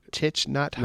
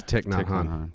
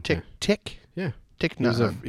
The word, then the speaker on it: Thich Nhat Hanh: So I'm going to butcher this name, but Thich Nhat Hanh? – No, it's Thich Nhat Hanh. – Thich Nhat Hanh.